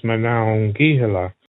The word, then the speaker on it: Monongahela: The proper Lenape pronunciation is Monongahela.